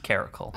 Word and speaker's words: caracal: Is that actually how you say it? – Yes, it's pronounced caracal. – Okay, I'm always like, is it caracal or caracal or caracal?